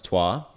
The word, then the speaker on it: Troyes: Troyes, yes, it's pronounced Troyes, in France, Palermo in Italy, recently purchased Bahia in Brazil in the first division, and Club Bolivar from Bolivia.